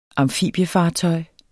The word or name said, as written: amfibiefartøj